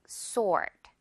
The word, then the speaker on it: sword: She has a magic sword. Sword. S-O라고 생각하세요, 여러분. She has a magic sword. 오케이. Okay. 이 W 묵음 확실히 알아둬야겠죠, 여러분?